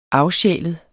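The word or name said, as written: AFSJÆLEDE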